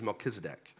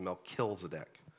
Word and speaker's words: Melchizedek: His name is Melchizedek, which I wrongly pronounced until pretty recently as Melchizedek.